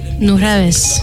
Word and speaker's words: Nujabes: Non, mais t'inquiète, il y a que moi qui le prononce. Parce que Fadi le prononce vraiment nul. C'est Nujabes.